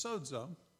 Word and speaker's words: sozo: For by grace are you saved, that's sozo, pronounce it sozo, but it's S-O-Z-O in Greek, but it's pronounced sozo, there's a D in there in the way we say it.